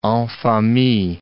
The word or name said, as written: en famille